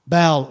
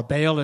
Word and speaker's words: Baal: Baal, or Baal, as sometimes people pronounce it.